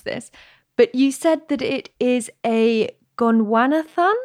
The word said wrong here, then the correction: Gondwanatherian: is that right? It's a Gondwanatherian, and it refers to the fact that these animals lived on Gondwana, and Gondwana was a former supercontinent.